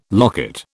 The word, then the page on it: locket: British English